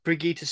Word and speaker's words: Brigitte: Is it Bridget Helm? I know you pronounce Brigitte. – It differently. Brigitte, sorry.